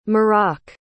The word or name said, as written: marrok